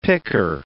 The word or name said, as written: picker